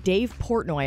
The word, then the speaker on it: Dave Portnoy: Well, the Barstool Sports founder, Dave Portnoy, I hope I'm saying his name correctly.